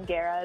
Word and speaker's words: Guerra: So it's not spelled like the other Aaliyahs, the singer, it's just A-L-I-A-H. – Yeah. – And the last name Guerra is a lot easier to say than it looks, but it's G-U-E-R-R-A.